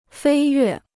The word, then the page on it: 飞跃: fēi yuè